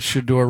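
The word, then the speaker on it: Shedeur: Is it weird that Shador rhymes with Masseur?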